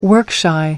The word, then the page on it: work-shy: American English